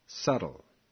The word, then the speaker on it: subtle: Pronounced subtle. Remember not to pronounce the B.